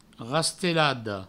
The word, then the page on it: rastelada: provençal; rastelado